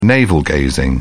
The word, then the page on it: navel-gazing: British English